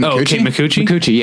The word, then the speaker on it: Micucci: Kate Micucci, yeah.